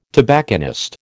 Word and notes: tobacconist: American English

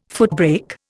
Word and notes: footbrake: Indian

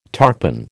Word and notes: tarpan: American English

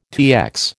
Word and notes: TX: American English

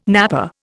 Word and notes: napper: Indian

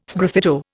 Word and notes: sgraffito: Indian

